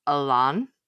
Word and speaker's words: Elaine: So I've pulled up a video how to pronounce Elaine. Okay. According to this YouTube video, French names pronunciation guide, we're all wrong and it's Alan.